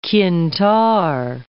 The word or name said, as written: qintar